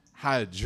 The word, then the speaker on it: Hajj: What action, what pillar of our faith rhymes with fudge? Hajj, right?